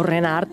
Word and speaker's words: Reinhardt: I és un festival que porta el nom de Django Reinhardt. – Correcte. – O Renard, perquè sí. Depèn de com ho pronunciem.